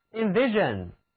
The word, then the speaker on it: invasion: Invasion S-I-O-N 是一个名词后缀，读音是 Zen，跟我来读 Invasion，侵略、入侵。